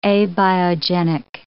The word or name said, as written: abiogenic